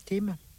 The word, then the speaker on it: Thyme: Parsley, Sage, Rosemary and Thyme, ou Tim, c'est en Y. Tim.